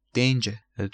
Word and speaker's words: danger: danger تو بریتانیایی آر آخر وقتی قبلش vowel باشه، وقتی قبلش حرف صدادار باشه تلفظ نمیشه.